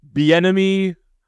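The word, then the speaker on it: Bieniemy: Bienemy on a chimney. – There it is. Yep. I was having a really hard time rhyming with Bienemy, and I don't think I'm going to do better than chimney.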